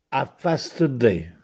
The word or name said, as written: Affasteddè